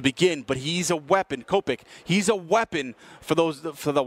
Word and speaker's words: Kopech: Kopech, Michael Kopech. I think that's how you pronounce his name, Kopech.